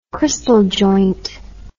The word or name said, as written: kristal joint